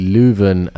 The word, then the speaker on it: Leuven: It's actually Leuven and it's in June. And apparently I've been pronouncing it very well, Leuven.